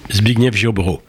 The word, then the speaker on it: Zbigniew Ziobro: Ainsi, à l'automne 2016, le ministre de la Justice, Zbigniew Ziobro, je ne sais pas si je prononce bien. – Zbigniew Ziobro. – Voilà.